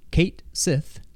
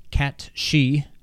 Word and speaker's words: Cait Sith: What I hope is Final Fantasy VII Original, there's a character who I always thought was called Kate Sith... C-A-I-T-S-I-T-H, but apparently it's pronounced Cat-She. I don't know. – What? – Cat-She. – S-I-T-H is she? – Yes. Yeah, C-A-I-T, I can believe if you want to say that's pronounced cat for some reason.